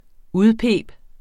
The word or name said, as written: udpibe